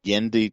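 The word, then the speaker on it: Genndy: Do you know how to say his first name? – Gendy Tartakovsky is Gendy? – What I have always heard. Gendy okay.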